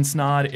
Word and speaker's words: Snod: My name is Ryan Snod. It rhymes with odd, and you're listening or watching the Rhymes with Odd podcast.